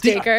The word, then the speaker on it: Dacre: That totally grabs the attention of Decray Montgomery. Did I say his name right? – Dacre? Decray? Wow. No, it's Dacre.